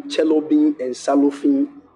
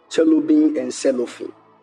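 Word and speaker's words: Cherubim and Seraphim: Neither are we Chelobin and Salofin Church. Are you listening to me? That's how they say it. That's why I'm saying it like that. That's how the Nigerians call it. Chelobin and Salofin.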